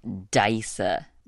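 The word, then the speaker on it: dgħajsa: Dysa.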